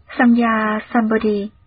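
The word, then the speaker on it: Samyak Sambodhi: Chữ Tam Miểu Tam Bồ Đề, nguyên âm Ấn Độ đọc là Samya Sambodhi.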